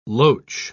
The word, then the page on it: loach: British English